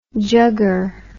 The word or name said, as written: jugger